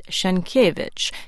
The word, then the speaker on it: Sienkiewicz: Thomas Sinkowitz went on to explain in an email exchange with us that he does not use the traditional Polish pronunciation of his name. A famous Polish novelist from the turn of the 20th century shares our listener's last name, but pronounces it Sienkiewicz, Henryk Sienkiewicz.